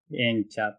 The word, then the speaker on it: 淹茶: を円って読むので、それを入れるお茶、円茶っていう、